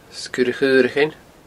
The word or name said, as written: Sgurr a Chaorachain